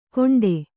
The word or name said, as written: ಘೋಂಟೆ